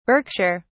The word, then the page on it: Berkshire: British English